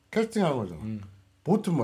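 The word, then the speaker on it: casting vote: 캐스팅 보드라고 알고 계신 분들이 많아서. – 캐스팅 보트지. – VOTE. – VOTE. 이 발음이 그렇게 안 돼? – 캐스팅 보트는 VOTE 보트입니다. 보트. 그 배 타는 보트가 아니고 투표하는 보트. 그래서 아까 캐스팅 보트 누구 이렇게 얘기했지?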